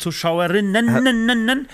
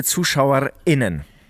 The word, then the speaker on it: ZuschauerInnen: Und man betont es ja wirklich. Liebe ZuschauerInnen. – Das ist die, nee. doch, das ist die richtige Betonung. Habe ich gelernt.